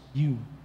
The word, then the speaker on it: ewe: The first time I used the word ewe-lamb, I pronounced it as ewe-lamb. So when I went down the stage, he brought me aside, bro, it's pronounced you.